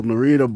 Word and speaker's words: Narita: There you go, Narita boy. – Yeah, it reminds like rhymes with Pat Morita.